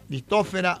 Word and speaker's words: litosfera: De todas ellas, solo la palabra atmósfera es esdrújula. Las demás son graves y llevan el acento sobre la sílaba fe. Estratosfera, ionosfera, biosfera, litosfera o hidrosfera.